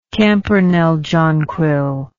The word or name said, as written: campernelle jonquil